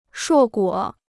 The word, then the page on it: 硕果: shuò guǒ